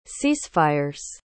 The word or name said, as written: ceasefire's